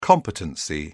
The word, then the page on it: competency: British English